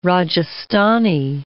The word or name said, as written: Rajasthani